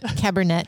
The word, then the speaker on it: Cabernet: It ends with a T, doesn't it? – No. – That's Merlot. – Cabernet. Cabernet. Yeah. – That's how you say it, right? – Yeah.